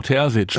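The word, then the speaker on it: Terzić: Ich habe gestern schon wieder die wüstesten Ausspracheproduktionen gehört von Terzic. Nein, Terzic. Ja, Edin Terzic. Genau, 38 Jahre jung. – Mit einem weichen S.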